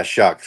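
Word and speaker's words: Shough: Yeah, shuck, sure. Shuck and oyster. There you go. Yeah, that's how I'll try to remember it.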